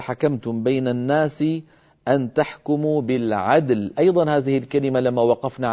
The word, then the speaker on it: بالعدل: فيجب بيان الدال وقلقلتها ويجب بيان اللام وبينيتها هكذا. أن تحكموا بالعدل، فنقلقل الدال ونبين شدتها ونبين ونعطي اللام حقها من البينية، بالعدل دون أن تميل الدال إلى شيء من الكسر عفوا، فتبقى الدال ساكنة واللام كذلك ساكنة.